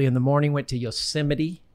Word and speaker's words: Yosemite: You just learned it's Yosemite.